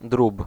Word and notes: volaille: France; [vɔ.laj]

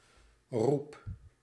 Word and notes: appel: France; /a.pɛl/

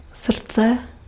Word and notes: générosité: France; [la.ʒe.ne.ʁɔ.zi.te]; /ʒe.ne.ʁɔ.zi.te/

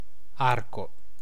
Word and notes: edge: /ɛdʒ/

